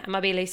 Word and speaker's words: amabile: How would you pronounce amabile? Yeah, amabile.